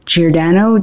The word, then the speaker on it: Giordano: And my name is spelled L-O-R-R-A-I-N-E, Giordano, G-I-O-R-D-A-N-O.